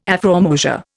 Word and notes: afrormosia: Indian

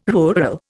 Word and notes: rural: Indian

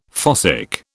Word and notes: fossick: British English

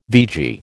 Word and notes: vegie: American English

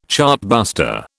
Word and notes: chartbuster: British English

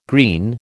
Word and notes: green: American English

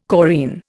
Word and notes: chorine: Indian

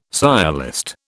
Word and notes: sciolist: British English

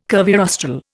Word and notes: curvirostral: Indian

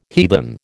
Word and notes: heathen: American English

